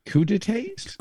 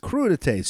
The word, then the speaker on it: crudités: Cut it into what? Crudités. Is that how it's pronounced? Crudités. Yeah. Oh boy. Crudités? Crudités. C R U D I T E S.